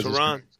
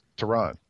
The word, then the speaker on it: Tyronn: Or as he said it, I believe he says Tyronn. – Tyronn. – Yeah. – But I keep hearing Tyronn.